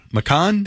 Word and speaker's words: Macan: That is not how you say it. – What's the word? – Macan? – It's a Macan.